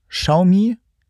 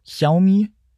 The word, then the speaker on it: Xiaomi: Xiaomi. Ja. Xiaomi.